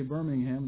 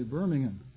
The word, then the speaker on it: Birmingham: Now, we say Birmingham, the British say Birmingham.